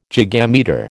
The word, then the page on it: gigametre: American English